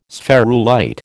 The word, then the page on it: spherulite: American English